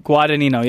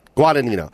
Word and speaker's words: Guadagnino: Vinny Guadagnino. – Yeah. – Did I say it right? – Guadagnino, yeah. – God damn it. Guadagnino. – There you go.